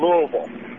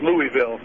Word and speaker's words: Louisville: Doves, when you're coming back through Kentucky, stop in Louisville. That's how you say it if you're from there, Louisville. But it's Louisville, yeah.